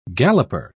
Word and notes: galloper: British English

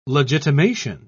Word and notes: legitimation: British English

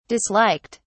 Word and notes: disliked: British English

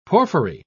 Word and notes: porphyry: British English

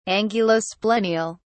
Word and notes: angulosplenial: British English

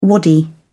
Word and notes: wadi: British English

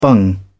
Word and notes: bung: British English

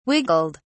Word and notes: wiggled: British English

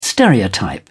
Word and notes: stereotype: British English

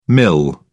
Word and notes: mil: British English

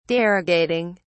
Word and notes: derogating: British English

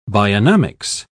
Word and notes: bionomics: British English

